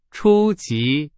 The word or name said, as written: 初级